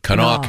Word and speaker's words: Kanawha: The larger point here is the West Virginia restaurant is it pronounced Kanawha County? – Kanawha County. K A N A W H A.